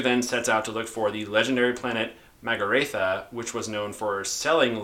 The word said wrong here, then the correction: Magrathea: I think it's pronounced Magrathea. – Magrathea, thank you.